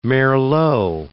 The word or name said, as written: merlot